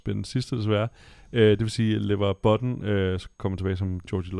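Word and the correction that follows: LeVar Burton: Og Lever Burton? – Hvad? Burton. – Burton. Tim Burton? Lever Burton. Er dårligt til at udtale hans navn.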